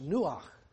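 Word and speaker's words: nuach (נוח): You may already know this, but the Hebrew word for rest is pronounced nuach.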